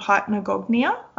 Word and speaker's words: hypnagogia: What he ended up saying was that it sounds like you have something called hypnagogia. I think that's how you pronounce it. – Yes.